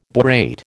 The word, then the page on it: borate: American English